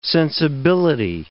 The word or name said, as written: sensibility